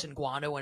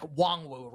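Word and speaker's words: Nwangwu: I thought it was Ngwanu, and it's wrong. It's like Wongwu, right? Kane Wongwu is how you pronounce his name.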